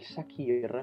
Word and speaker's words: Sakhir: Si è preso il titolo sul circuito del Sakhir, l'ho detto bene? – Sì, l'hai detto giusto.